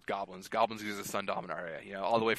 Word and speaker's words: Dominaria: I believe it's Domaria. Uh, Dom, I can never pronounce it. Domaria. Dominaria. Dominaria. Dominaria. Yeah. There's, there we go.